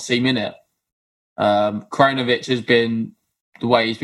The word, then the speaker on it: Krajinovic: It's another 6-2, 6-1 from, and I apologize from the last episode, it is Krajinovic. So better pronunciation this time.